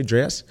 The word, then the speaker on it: Dre's: Oh, that's what you say, Dre's? – Dre's. You say it like it's Andrea's. – Right, exactly.